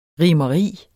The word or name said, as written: RIMERIET